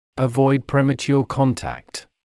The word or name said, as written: avoid premature contact